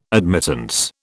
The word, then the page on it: admittance: British English